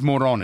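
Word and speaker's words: Morón: The town on the Caribbean coast, it's M-O-R-O with an accent over N. How do you pronounce that town? – Moron? – It's Moron, is it? – Yes.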